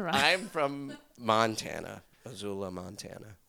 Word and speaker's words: Azula: No, Azula. – It's like Missoula. – Missoula, but Azula. – People say Z's, but it's... – S's. – It is S's, but you're pronounced them Z's.